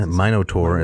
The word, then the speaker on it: Minotaur: So our Minotaur in the song, or Minotaur, is it? – Yeah, Minotaur, as they pronounced it.